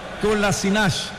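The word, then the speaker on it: Kolašinac: Kolasinac. Ellos pronuncian el final también de sus apellidos con la Che, el Ish o el Ash.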